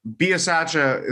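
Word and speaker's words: Bisaccia: And as we talked about last week, going into Buccaneers game, there, Biasaccia, is that how you say it? – Yep.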